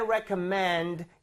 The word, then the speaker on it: recommend: 근데 발음 어떻게 해야 돼요? – Recommend. – recommend라고 발음을 하셔야 됩니다. So 여기 R 이 부분이 re가 아니라 re 느낌. Right?